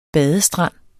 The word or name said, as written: badestrand